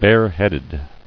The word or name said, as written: Barehead